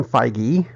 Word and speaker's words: Feige: After they finished the Captain Marvel movie, Kevin Feige, and that is how it's pronounced, I called Marvel and asked,